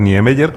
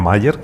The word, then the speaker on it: Niemeyer: Niemeyer, como decimos aquí, o Mayer, como dicen, como dicen allí, lo pronuncian más alemán que nosotros,